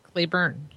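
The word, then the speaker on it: Cleburne: Is it Claiborne, Texas? – Claiborne? – No, it's not an O. It's a U. C-L-E-B-U-R-N-E. – Claiborne, yeah.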